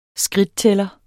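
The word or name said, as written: SKRIDTTÆLLER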